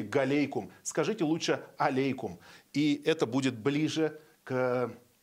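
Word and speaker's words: алейкум (عليكم): что здесь есть такой звук алейкум. И неправильно говорить галейкум, ассаляму галейкум, то есть или ассаляму галейкум. Вот так говорят, нужно говорить ассаляму алейкум.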